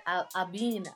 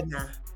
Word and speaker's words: Abena: You said Abina. How do you say it? – Abana. – Abana. Abana.